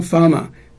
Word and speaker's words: Pharma: 加好加低的两个音节就好，啊，所以我总甲买啊，迄个 CIST 啊，吼，读掉 Pharma 是药学的总称啦，哦，啊，但是有真侪人啦，吼，讲实在，这个发音嘛，吼，伊甲他听到吼，伊就感觉讲 Pharma 大概是 F 开头，其实是 PH 开头，发音嘛是 F 的发音啦，吼，啊，所以讲常常拄到拢爱讲。